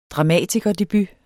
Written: dramatikerdebut

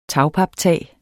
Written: tagpaptag

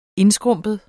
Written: INDSKRUMPENT

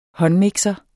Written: håndmixer

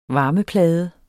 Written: varmeplade

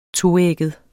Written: toægget

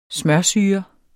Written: smørsyre

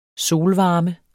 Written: solvarme

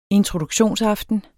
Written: introduktionsaften

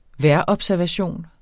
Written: vejrobservation

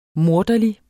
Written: morderlig

